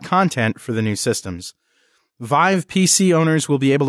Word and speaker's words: Viiv: From Associated Press also, chipmaker Intel launched its Viv, and I don't even know how to pronounce this. – It's Vive. – Vive.